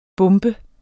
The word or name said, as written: bump